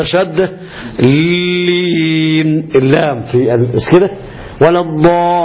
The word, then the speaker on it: الضالين: أدي معنى كلام شيخنا، ها، والتشديدات الأربعة عشرة، وإخراج الضاد من الظاء، يعني تنطق غير المغضوب عليهم، المغ ولا الضاء، ما تقولش ولا الظاء كلغة قوم، كلهجة عند قوم، ولا الظالين، ما ينفعش عندنا ولا الظالين، كان ينفع عند قوم ولا الظالين، فعندنا ما ينفعش ولا الظالين، اللي عندنا ينفع، ولا الضالين.